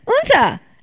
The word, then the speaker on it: Odense: Like oh, so it's O-D-E-N-S-E. – That's exactly what I said. – Really? – Yes. Oh. So I've been pronouncing it Odense, like the like dumb ass American that I am, and for days. And people look at me with a questionable Odense expression, like, I don't know what that is. – Yeah. – And then I start talking about it. Yeah, you know, it's the little island that's an hour and a half train ride. And they go, Unsa.